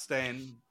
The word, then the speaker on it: Stein: It looks like Stein. Oh, okay. Stein, but gotcha. It's pronounced Stain.